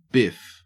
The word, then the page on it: biff: Australian English